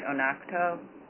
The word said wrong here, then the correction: Oconto: Oconto? – Oh, thank you.